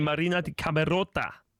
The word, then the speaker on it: Marina di Camerota: Og på ferie i Marina di Camerota.